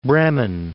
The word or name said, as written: Bremen